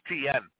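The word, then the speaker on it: Etienne: ETN. He okay. pronounces it ETN.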